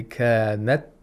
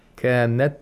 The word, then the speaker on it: كانت: So you have to say kanat, kanat, without aspiration.